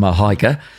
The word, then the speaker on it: Mihalyka: Now, for a start, there's a silent L in there. – That's it, yeah. Christmas time, very appropriate. – Yep. – Angels. – That's right. I've been practicing for the podcast, yep. Mihalyka.